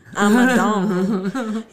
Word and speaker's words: Amidon: Amidon. Yeah. If people always mispronounce my name constantly. – Yeah. – Yeah, I guess it's just French and, you know, LC is the number one, just goes on.